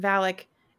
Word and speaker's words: Valak: Valak.